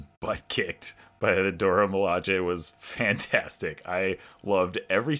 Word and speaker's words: Milaje: okay, so you guys are going to have to help me with the pronunciation. Dora Milaje? Milaje? Milaje? – Milaje. – Milaje. Okay, Dora Milaje.